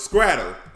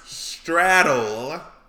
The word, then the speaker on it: straddle: So the way I was brought up, I thought it was scrattle. Apparently, it's pronounced straddle. So, for the Southerners out there, is it pronounced scrattle or straddle?